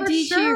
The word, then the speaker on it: Dietschy: Sarah Dietschy rhymes with peachy is a watermelon.